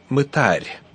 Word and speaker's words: мытарь: О том, что ударение нужно ставить именно так, мы однажды уже указывали. Напомним, где эту информацию можно найти в Библии. Евангелие от Матфея, 18 глава, 17 стих говорит, «Туда будет он тебе, как язычник и мытарь». И там стоит ударение.